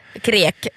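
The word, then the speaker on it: Kriek: Jag brukar säga Krik, jag vet inte hur man ska uttala det. – Krek.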